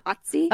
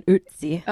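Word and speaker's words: Ötzi: Otzi? – Oh, yeah. Because it's got the umlauts over the O, so I'm thinking Otzi. – Okay. Otzi.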